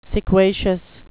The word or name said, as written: sequacious